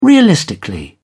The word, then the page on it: realistically: British English